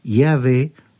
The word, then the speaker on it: Yahvé: Un nombre tan sagrado para el pueblo que ni siquiera se atrevían a pronunciarlo, y que se trataba, sin duda, del tetragrama o conjunto de cuatro consonantes del nombre de Dios de Israel, YHWH, pronunciado Yahvé o Jehová.